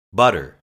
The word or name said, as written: butter